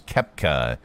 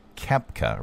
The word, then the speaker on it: Koepka: Brooks, how do you pronounce Brooks' name again? – Brooks Koepka. – Yeah, I said that. Brooks Koepka, right? – Brooks Koepka.